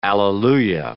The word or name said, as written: alleluia